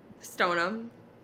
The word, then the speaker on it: Stoneham: It's stone ham. That's not how it's pronounced. – How do you pronounce it? – Stone-um. – Yeah, okay. So does the Yeah, but it's whatever. – It's whatever. I sent you you probably saw the video anyways, but your high school, my high school actually call it Stone-um High School. – I'm well aware. Stoneham. – So suck it. That's what it is. – Stoneham. It's spelled Stoneham. – As people say Stone-um.